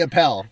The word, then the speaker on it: Appell: Jesse Appell. – Jesse Appell. Gosh, I'm so bad at this. – Not a problem. – Yes, please introduce yourself. – Sure. So my name is Jesse Appell.